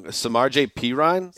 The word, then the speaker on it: Samaje Perine: Samaje Perine? – That's right.